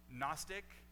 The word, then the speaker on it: Gnostic: The G in Gnostic is silent, just like the K in knowledge is silent.